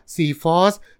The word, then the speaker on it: psephos: ซีฟอส